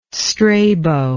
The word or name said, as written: Strabo